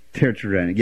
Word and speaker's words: teratogenic: How do you pronounce that when you say it causes birth defects? Tetragenic. Teratogenic, yeah.